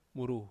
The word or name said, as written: muru